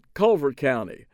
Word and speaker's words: Calvert: Louis was comptroller of Maryland and an unapologetic, unrepentant booster of Calvert County. He pronounced it Culvert County, where he was born and raised and developed his political muscle.